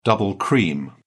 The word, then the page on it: double cream: British English